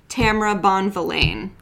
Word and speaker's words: Bonvillain: Is it Bonvillain? – Yeah. I always say Bonvillain because I know. Think it's great. – I always pronounce it wrong too, but I'm pretty sure it's Tamara Bonvillain.